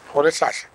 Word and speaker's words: Rolihlahla: Rolihlahla.